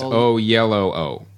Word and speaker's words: Oyelowo: Yeah, David Oyelowo. – Oyelowo. We got a bunch of tweets telling Oyelowo. Us how to pronounce it. It's Oyelowo.